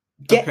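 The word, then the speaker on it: Ge'ez: Gez, okay.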